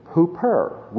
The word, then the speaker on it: huper: There's another one that's extremely important in the original language, and that's the preposition huper. We would spell it in English H-U-P-E-R.